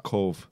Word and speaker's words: Cobh: My sister-in-law, her brother played with Roy Keane for about 2 or 3 months when he was at Cove Ramblers before he went up to do the Irish. – Is it Cove? – Cove. Spelled C-O-B-H.